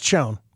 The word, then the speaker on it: Chone: It's pronounced Chone. Okay? Get it right.